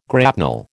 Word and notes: grapnel: American English

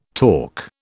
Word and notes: torc: British English

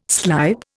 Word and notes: slype: Indian

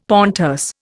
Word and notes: pontes: Indian

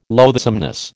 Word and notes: loathsomeness: American English

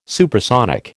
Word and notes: supersonic: American English

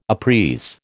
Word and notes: uprise: American English

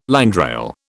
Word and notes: landrail: British English

